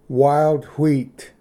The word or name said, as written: ᎤᏣᎴᏍᏗ